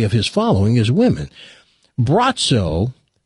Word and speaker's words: Braco: Spelled B-R-A-C-O. – Bratzo. – Spelled B-R-A-C-O, but pronounced Bratzo.